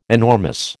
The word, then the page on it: enormous: American English